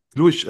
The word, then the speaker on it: Cluj: Und das letzte ist Steaua Bukarest oder CFR Chloe? Ich hoffe, ich habe die richtig ausgesprochen. – Jetzt habe ich nicht verstanden. Ah, Chloe, äh, Glusch meinen Sie? Klusch, okay, Cluj heißt es, genau.